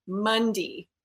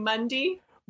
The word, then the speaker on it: Munday: your name is said Mundy. Is this true? – That's correct. – So we actually say your name Mundy? – And that is the most intelligent, because that's the way they say Monday here in the South. But what's funny is, like, even though that's the Southern way of saying Monday, it's MUNDAY.